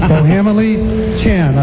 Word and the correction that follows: Juju Chang: Juju Chang. – Juju Chang.